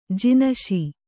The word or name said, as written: ಜಿನಶಿ